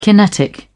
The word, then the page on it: kinetic: British English